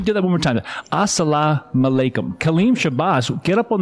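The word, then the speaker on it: As-salamu alaikum: You got to teach me how to say that. – As-salam alaikum. – And that means peace be with you.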